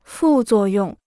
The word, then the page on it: 副作用: fù zuò yòng